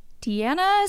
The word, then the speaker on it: Diana: I've accidentally called you Diana a few times, though. – It happens, and I can see why, because my name is spelled Diana.